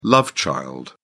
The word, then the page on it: love child: British English